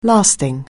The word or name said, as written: lasting